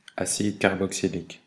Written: acide carboxylique

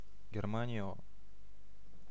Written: Germany

